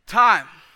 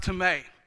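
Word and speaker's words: tome (τιμή): Time. It looks like time, but it's actually pronounced tome. Tome.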